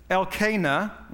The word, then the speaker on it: Elkanah: but it's Elkanah, okay, according to the accents that's in that list in the front of your Bible.